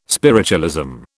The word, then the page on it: spiritualism: British English